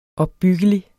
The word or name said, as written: opbyggelig